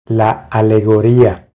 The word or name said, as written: La alegoria